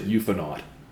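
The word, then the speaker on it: ufonaut: A close range sighting of a UFO and one or more UFO knots. You did not mention UFO knots. – I can't believe you've seen all 10 episodes of Hellier and you did not pronounce it euphonaut.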